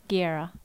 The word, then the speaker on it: Guerra: S-A-V-E, like save? Like save Rio? – Guerra, yeah, Guerra.